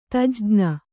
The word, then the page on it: ತಜ್ಞ: tajṇa